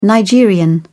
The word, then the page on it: nigerian: British English